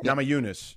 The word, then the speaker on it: Namajunas: Rose Namajunas, is that how you say it? – Namajunas, yeah.